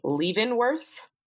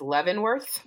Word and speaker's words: Leavenworth: So, I was pronouncing the prison wrong. – Okay. – I kept saying Leavenworth. Oh. It is Leavenworth.